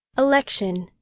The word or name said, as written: election